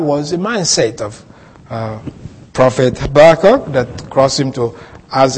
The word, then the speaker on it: Habakkuk: Habakkuk, I know some people pronounce Habakkuk, but Habakkuk.